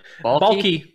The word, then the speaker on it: Baalke: Yeah, Baalke. that was Trent Baalke. Yeah. – Hey, we confirmed it. That's how you say it. – I know. – What is it? – Baalke.